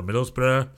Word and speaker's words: Middlesbrough: Zach Steffen had his shaky little debut, but he's playing now for Middlesbrough. Obscure Americans. – How do you say that word? – Middlesbrough. Bruh. If there's a borough in England, just say bruh.